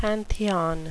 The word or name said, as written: pantheon